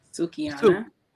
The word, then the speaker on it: Sukihana: Sukihana.